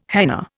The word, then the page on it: henna: Indian